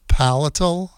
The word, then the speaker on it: palatal: We'll see what the great doctor says, if I can take out my palatial stent, palatal, is that how it's pronounced? P-A-L-A-T-A-L, stent.